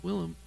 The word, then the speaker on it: Willem: Did I say William? – You said William. It's Willem.